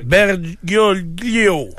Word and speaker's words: Bergoglio: Bergoglio.